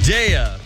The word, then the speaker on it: Daya: Daya. – Daya. – That's right. – Actually, it's pronounced Daya, but that's good enough.